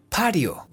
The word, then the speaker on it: patio: sí, esa se escribe igual que en el español, solo que en inglés, en este contexto, la T suena como una R. Pario. Sí, como patio.